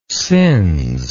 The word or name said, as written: sins